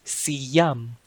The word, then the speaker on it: siyam: So it's spelled as W-A-L-O. Walo. Walo. Next is siyam. Siyam. Siyam is... That's right. It's 9. So 9 is siyam. Siyam. So it's spelled as S-I-Y-A-M. Siyam.